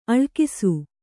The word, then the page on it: ಅಳಕಿಸು: kannada